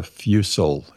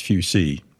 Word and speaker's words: fusil: What people mispronounce, they call it a fusil. It's fusee. It's a French term.